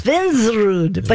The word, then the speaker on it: Svensrud: We are welcoming Chris Svensrud from Rude. I want to say it right. – Svensrud. – Svensrud.